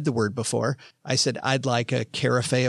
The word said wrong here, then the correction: carafe: of wine. And the guy, because it's spelled C-A-R-A-F-E. And the dude looks at me and goes, absolutely. I'll bring back a carafe of that wine.